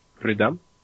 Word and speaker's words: Prudhomme: Prudhomme. It's French, and yeah, have fun trying to pronounce it whenever you do try.